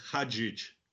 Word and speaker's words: Hadžić: My family name is Hadžić. – Hadžić. See, I knew I was going to mess up. It looks like a Z to me, but there's a whole pronunciation guide in this book.